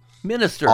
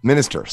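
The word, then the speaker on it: MxMxSxTxRR: So Minister, not Monster. Minister.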